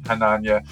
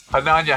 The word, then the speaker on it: Chananya: And it's just great to meet Chananya. And I'm going to probably say his name at least once today. So I'm going to ask for patience in advance. Chananya, how are you today, my friend? – Thank you so much for having me.